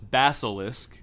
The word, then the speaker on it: Basilisk: There's no K after the S. It's Basilisk. So that's the first thing. – Basilisk. It's like the same as Harry Potter, though, right? – Yeah, it's Basilisk.